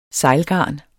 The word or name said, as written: sejlgarn